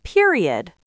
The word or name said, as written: period